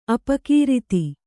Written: ಅಪಕೃತ

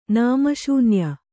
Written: ನಾಮಶೂನ್ಯ

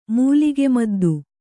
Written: ಮೂಲಿಗೆಮದ್ದು

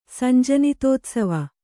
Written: ಸಂಜನಿತೋತ್ಸವ

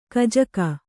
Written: ಕಜಕ

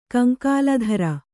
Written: ಕಂಕಾಲಧರ

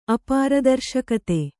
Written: ಅಪಾರದರ್ಶಕತೆ

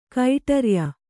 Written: ಕಡುರಯ್ಯ

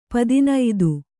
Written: ಪದಿನೈದು

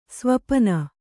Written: ಸ್ವಪನ

